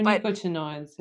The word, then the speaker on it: neonicotinoids: they're called neonicotinoids. I'm probably pronouncing that wrong. – Neonicotinoids. – Yeah. Neonicotinoids.